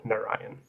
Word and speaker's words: Narayan: Yeah, so traditionally the na is emphasized a lot more. But because of my phonetic upbringing, I just go with Narayan.